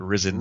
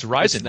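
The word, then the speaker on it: Rizin: I saw the gentleman that did the play-by-play calling it Risen. Numerous times. It's not that difficult to pronounce. It's Rising. It's like Japan, the rising sun.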